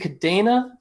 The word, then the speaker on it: Kadena: Is it Kadena? It's Kadena. Okay. Kadena's. It's one of those ambiguous pronunciations based off the way it looks.